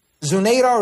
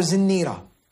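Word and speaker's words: Zinnirah: Zunaira or Zinira. Right? The name is pronounced two ways.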